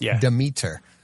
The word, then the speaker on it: Demeter: Now the last voyage of how do you say it? Demeter? – The Demeter.